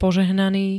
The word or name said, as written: požehnaný